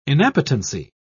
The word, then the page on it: inappetency: British English